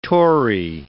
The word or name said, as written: Tory